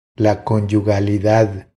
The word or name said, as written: La conyugalidad